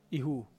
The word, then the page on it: hu: fall